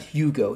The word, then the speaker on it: Hugo: It is Victor Hugo. It's not Hugo.